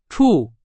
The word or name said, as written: 处